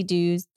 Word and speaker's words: Duhs: No, but yeah, my website, Tracy Dues. The last name is spelled D-U-H-S. Pay your dues, but with an H instead of an E.